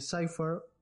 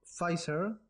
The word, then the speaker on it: Pfizer: Asimismo, el laboratorio Pfizer, que eh, la semana pasada lo pronuncié mal, lo pronuncié Cypher, pero es que yo en mi mente eso era una PZ y no sé, está raro. El laboratorio Pfizer,